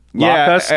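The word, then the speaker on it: Lokhust: Lockhust? Yeah, I think it's just pronounced like Locust. – But it's spelled L-O-K-H-U-S.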